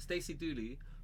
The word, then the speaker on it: Stacey Dooley: Her name is so funny that when I say it, I feel like I've said it wrong, but I'm saying it right. Stacey Dooley.